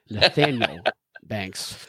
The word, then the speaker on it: Nathaniel Banks: Excuse me, National Banks would not be the proper way to pronounce that. It's Nathaniel Banks.